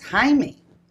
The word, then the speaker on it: Jaime: But also, Jamie in Spanish is Jaime. The way that my name is spelled, J-A-I-M-E, oh. – Which my mother's and father's intent was the French, J-A-I-M-E.